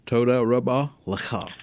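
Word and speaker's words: Toda Rabba Lecha (תודה רבה לך): Well, in Hebrew, I hope I'm saying it right, Toda Rabba Lecha, my friend.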